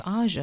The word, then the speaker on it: Aja: That was my dad's favorite song, but he decided to throw a curveball and pronounce it Aja. But trust me, I didn't know until years later. It's like, no, it's Aja.